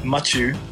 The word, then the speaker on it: Machu: Okay, it's pronounced Machu. – Machu, and you f***ed up. You said Michu, like please to Michu.